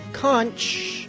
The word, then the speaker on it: conch: And hey, I learned how to pronounce conch. It is not conch, it's conch.